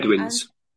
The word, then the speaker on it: Bedouins: Can you please talk about the Benduins? I think that's how you say it. And Bedouins.